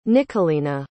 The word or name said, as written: nicholina